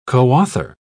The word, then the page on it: coauthor: British English